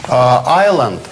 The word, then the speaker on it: island: «С» почему не произносится? – Здесь? – Да. – Ну, здесь это потеряно в произношении. И, кроме того, это отличает «island» от «Iceland».